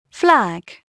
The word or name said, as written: flag